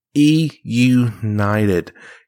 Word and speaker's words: eUnited: Last and final subject of today's part of the Morgan Man Sports Podcast, and that is your Call of Duty World Champions E United. Yes, that's right, guys. I'm saying that right. It's not 100 Thieves. It's not Optic Gaming. It's not Gen G. It is E United.